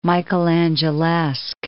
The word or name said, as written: Michelangelesque